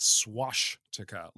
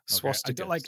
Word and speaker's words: swastika: I can't believe, Reese, this is not the first time I've had to correct your pronunciation of this word, but it is not swashtika, like swashbuckling. Okay? – Swastika, sorry.